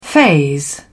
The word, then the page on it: phase: British English